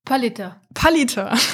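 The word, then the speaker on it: Pallieter: Genau, und dieses Buch hieß, wir wussten beide nicht, wie wir es richtig aussprechen, Pallieté oder Pallieta? – Warte, nee. Was hat Google gesagt? Pallieta, okay.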